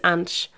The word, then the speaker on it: anys: Yeah, tinc 30 anys. It's a bit of a weird one to pronounce. We think of like, it's a woman's name, Ange. So just think of a woman's name, that called a person called Ange.